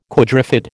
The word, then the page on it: quadrifid: American English